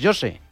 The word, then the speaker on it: José: Pero José en portugués, José. – José. – ¿Cómo? – José. – José. – José. – Pues como José, como dice José.